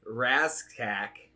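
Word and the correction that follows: Rasczak: Oh, you are butchering the name. It's are you mean Radchak?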